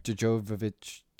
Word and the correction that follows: Jovovich: I always either add in or lose a syllable in her name. – Yeah, it's a fun game I like to play called Butcher Someone's Name. Yeah, Mia Jovovich.